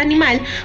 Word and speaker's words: anima: Hay que tener en cuenta que la palabra animal proviene del latín animal. Uh-huh. Animal. – Anima. – Con las anima. – Ay, Dios mío. Ay, Dios mío, mío. – Con acento en la A.